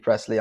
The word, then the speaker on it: Pressley: And then, if you want to be one of those people who just, like, you know, badgers me, I am JP Pressley on Instagram.